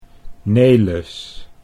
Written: Nelis

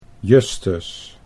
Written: Justus

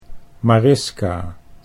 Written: Mariska

